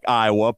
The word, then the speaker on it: Iowa: Everywhere else in the country, it's Iowa, but I guess if you live in Louisiana, the town is spelled like Iowa, but it's pronounced Iowa.